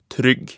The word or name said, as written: trygg